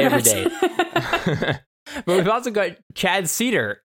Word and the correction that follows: Cider: I think that's how you pronounce his last name. Hey, Chad. – So close. Cider. – Cider. Oh, geez. Okay, so it's easy to remember. It's like apple cider.